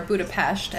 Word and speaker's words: Budapest: But so to talk a little bit about Budapest as a city, or Budapest as it's pronounced there, because an S sound is pronounced like an SH sound.